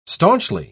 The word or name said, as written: staunchly